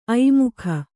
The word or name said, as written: ಐಮುಖ